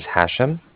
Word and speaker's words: Hashem: My name is Hashem. That's H-A-S-H-E-M, like mother at the end. And I'm calling from Cardiff. That's spelled C-A-R-D-I-F-F, like Frank.